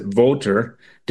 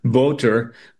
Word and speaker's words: Wouter: And Wouter Bassong, I think they pronounce it Voter, Voter Bassong, was developing specific bioweapons that could basically attack specific genetic tribes of different African tribes.